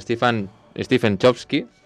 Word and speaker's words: Stephen Chbosky: També tenim una altra comèdia, en aquest cas musical, i també drama, es diu Querido Evan Hansen, dirigida per Stephen Chbosky, suposo que es dirà així, ben, no sé si es pronuncia així, però bé. – Ho has dit bé, bravo, no t'amoïnis.